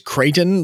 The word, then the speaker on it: Creighton: It's Creighton, like with an A sound.